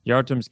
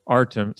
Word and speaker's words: Artem: Yartum's coming, or Artem. sorry, I keep pronouncing the Y because it says it right there on the screen.